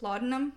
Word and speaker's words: laudanum: Laudanum? – Yes.